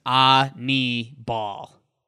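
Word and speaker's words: Aníbal: Just reminds me of a pretty little girl in the South, Annie Bell. It's like, Annabelle. – Annie Bell had a great game yesterday. So good for Sanchez. – You'd like the pronunciation on Baseball Reference. It says Ani-ball.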